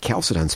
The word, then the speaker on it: Chalcedon: Chalcedon, spelled with a CH, but it's pronounced like a K.